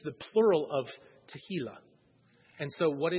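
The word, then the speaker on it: Tehillah (תהלה): Next word is H8416, and it is pronounced Tehillah, with a little more H. I can never do the Hebrew H. I can't do it. It's Tehillah. Sounds like tequila if I say it like that. It's not tequila.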